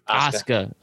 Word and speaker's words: Asuka: Nia Jax and Shayna Baszler defeated Azuka and Charlotte Flair. How we feel about Asuka? Okay, no more mispronunciations. Asuka and Shayna Baszler and Nia Jax and Charlotte Flair with we have brand new Women's Tag Team Champions.